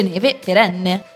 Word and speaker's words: Neve XN: Stiamo parlando della storia di Neve Perenne, che si scrive Neve XN, ma si legge Neve Perenne, che ha inventato una nuova tecnologia capace di produrre neve artificiale che sembra reale anche sopra gli 0 gradi.